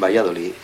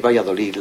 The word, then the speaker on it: Valladolid: porque por ejemplo en Valladolid, como podéis ver al pronunciarlo, no pronunciamos la L, es decir, la pronunciamos Y.